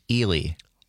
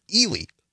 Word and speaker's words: Ely: That's the first correct pronunciation I've heard. Ely. Ely, Minnesota. Well, thank you.